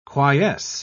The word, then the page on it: quiesce: British English